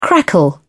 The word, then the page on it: crackle: British English